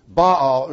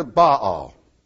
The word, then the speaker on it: Baal: Baal, we sometimes call him Baal, but it's really two syllables.